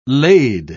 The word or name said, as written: laid